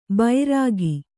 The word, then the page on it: ಬೈರಗಿ: kannada